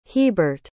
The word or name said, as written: hebert